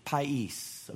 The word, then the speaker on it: pais: Jesus is described with a word in the Greek language, pais. Okay, P-A-I-S is how we spell it in English, pais.